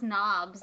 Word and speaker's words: Nobbs: Albert Noobs, Harry Potter. – I think that's pronounced Nobbs.